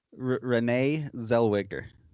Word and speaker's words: Renee Zellweger: Renee Zellweger.